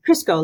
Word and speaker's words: Kryskow: Yep, Kryskow, like good, oil.